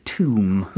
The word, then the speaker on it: tomb: 그러니까 이야기를 찾는 I'm like the tomb. 오, tomb이라는 발음을 들으셨는데 철자는 T O M B가 됩니다.